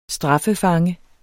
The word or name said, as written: straffefange